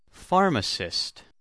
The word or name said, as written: pharmacist